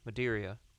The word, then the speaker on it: Madeira: Madeira finish. I hope that's how you pronounce it. – Good. – M-A-D-E-I-R-A. Madeira. Madeira. Madeira. We're going with Madeira.